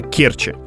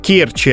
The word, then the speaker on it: Керчи: А нам сказали, так, главное не перепутайте, местные жители очень ревностно относятся к произношению, правильно, в Керчи, а не в Керчи.